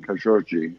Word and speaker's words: Khashoggi: There's a local pronunciation, Khashoggi, which distinguishes between the two G's. The first G is hard and the second G is soft.